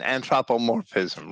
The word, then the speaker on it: anthropomorphism: There's one last thing, and that's like, does God have a mouth, or is this an anthropomorphism? Is that how you say it? – He's actually quoting, I'm sorry, an anthropomorphism, right?